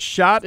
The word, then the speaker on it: Schott: Okay, first of all, is it Schott? Am I saying that right? – Yeah, actually, you're saying it correctly, so great job.